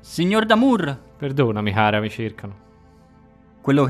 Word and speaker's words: D'Amour: Sei al bancone, davanti a una bionda carina dall'aria distratta, eh, che ti chiede: Damur? E come si scrive? Può farmi lo spelling? – Eh, come si scrive come in francese, cara. D apostrofo e poi Amour. A-M-O-U-R. – Come l'amore.